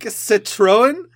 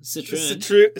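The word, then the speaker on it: Citroën: Citroen. Is that how you pronounce that? – Yeah. – C- yeah, Citroen.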